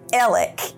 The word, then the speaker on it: Alex: So his name is pronounced Alec, even though it's spelled A-L-E-X, but whatever, I'm over it.